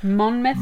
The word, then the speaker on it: Monmouth: Is it the first syllable, isn't it? The first syllable that's stressed? So you say... – Monmouth? – Monmouth? – Or Monmouth? – I think it's Monmouth.